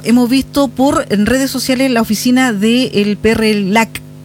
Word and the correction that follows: PRILAC: ¿Así se pronuncia? – PRILAC. – Sí, el sí. PRILAC, ahí sí.